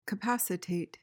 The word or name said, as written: capacitate